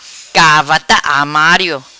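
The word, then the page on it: Gavarta Armario: patua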